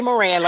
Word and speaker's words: Moran: Charity Moran, like more and more.